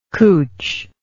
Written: cootch

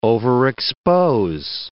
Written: overexpose

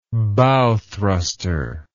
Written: bow thruster

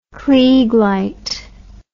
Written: kleig light

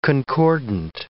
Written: concordant

